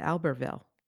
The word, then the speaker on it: Albertville: And we're already going to have an issue because I would pronounce it Albertville. – Oh, yeah. Probably we should be pronouncing it Albertville. – So before January, we have to get, you and I have to decide, are we going to use the English pronunciation or the French pronunciation? – We.